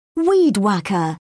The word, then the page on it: weed-whacker: British English